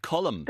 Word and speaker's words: Colm: How do you pronounce the name Colm? – Colm. – Come again? – Colm. – Ooh, that's a no. – I would pronounce the L in it. – Yeah, Colm.